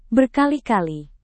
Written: berkali-kali

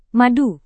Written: madu

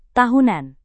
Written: tahunan

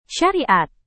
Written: syariat

